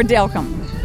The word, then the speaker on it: Delcambre: And so they added a day just for Delcambre.